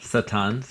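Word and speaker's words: Satan (שטן): It's the Hebrew word, Satan.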